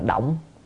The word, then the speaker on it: động: Ở đây chúng ta phải đọc là động, động là thấu suốt.